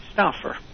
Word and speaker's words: Stauffer: Stauffer. – Spell it. – S-T-A-U-F-F-E-R.